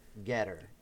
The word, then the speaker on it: Gettr: Okay, so the website is called Getter, and that is spelled G-E-T-T-R, Getter.